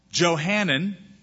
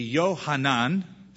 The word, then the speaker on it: Johanan: Johanan, or the Hebrew pronunciation would be Yohanan. So if I slip into that, it's one or the other. Yohanan or Johanan, however you want to say it.